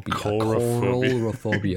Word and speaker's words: Coralophobia: And then I'm reading things that say, like, earlier in Coulophobia. Every time. – Oh, hang on. I'm going to listen to your pronunciation. Coulophobia. Okay. – Coralophobia. Coralophobia. I have a fear of coral.